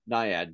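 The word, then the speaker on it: Nyad: So speaking of Annette Bening is getting a lot of attention for playing the swimmer in, is it pronounced Naiad? – Naiad, Diana Naiad, yeah.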